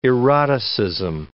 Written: eroticism

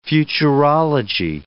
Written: futurology

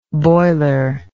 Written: boilerless

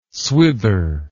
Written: swither

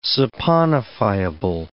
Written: saponifiable

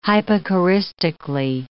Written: hypocoristically

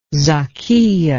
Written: zakiya

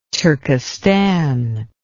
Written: Turkistan

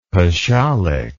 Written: pashalik

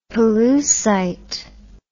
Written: pollucite

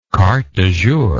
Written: carte du jour

